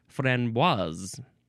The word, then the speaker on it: framboise: You do actually pronounce the S, and I didn't know that, so next time I am talking about this framboise. And somebody says framboise, I shall correct them. I shall smack them in the face and say, no, no, no, it is pronounced framboise.